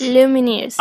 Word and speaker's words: Lumineers: Lumineers.